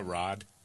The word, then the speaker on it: Raad: R-A-A-D. What's the pronunciation of that? Rod? – Yeah, Rod.